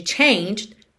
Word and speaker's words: changed: O E de changed não é pronunciado. E o D é uma consoante oclusiva que vem só fechando esse som e pode aparecer mais, menos ou nada.